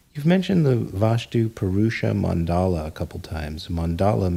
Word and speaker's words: Purusha: Okay, Purusha is spelled P-U-R-U-S-H-A.